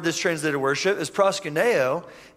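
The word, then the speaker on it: proskuneo (προσκυνέω): The Hebrew word, so I listened to how you pronounce this like 20 times just so I could impress you guys, okay? shakah. And then the Greek word is proskuneo.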